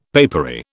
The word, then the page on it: papery: British English